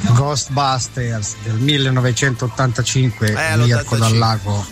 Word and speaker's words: Ghostbusters: Con la pronuncia inglese Ghostbusters.